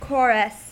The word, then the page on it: chorus: American English